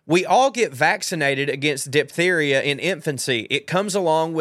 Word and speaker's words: diphtheria: And no, we don't pronounce the H after the P, so diphtheria, okay.